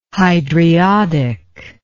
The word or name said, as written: hydriodic